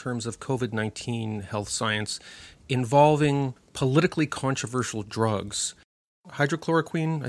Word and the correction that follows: Hydroxychloroquine: I think, is that how you pronounce it? Hydroxychloroquine.